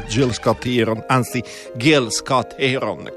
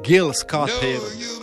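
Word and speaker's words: Gil Scott-Heron: Restate all'ascolto perché tra poco andiamo in scena con Teatro Nucleo e io vi invito di prendervi cura di voi con la voce di Gil Scott Heron, come mi hanno detto che si pronuncia. Grazie Andrea, il mio amico, che giustamente Gil Scott Heron, I'll take care of you.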